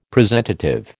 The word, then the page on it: presentative: American English